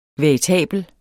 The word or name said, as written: veritabel